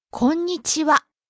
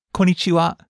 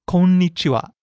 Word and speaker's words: konnichiwa: Konnichiwa. – Hello. It's not konnichiwa, it's konnichiwa. You really have to hit the N sound in there, the N syllable before ni.